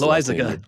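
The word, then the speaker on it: Loaisiga: Jonathan Loaz, I, Lo, I can never pronounce Loizaga.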